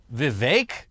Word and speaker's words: Vivek: A big standout for the night, and the one who will have received the biggest bump in notoriety, was definitely Vivek. Is that how he says it? – I guess so. – It says it rhymes with cake.